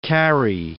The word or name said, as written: Cary